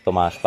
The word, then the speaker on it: Tomáš: In Czech, we pronounce it Tomáš Palenicek, but you did well.